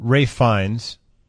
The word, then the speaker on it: Ralph Fiennes: And I don't know how to pronounce it. Ralph Fiennes, is it? – Ralph Fiennes, yeah.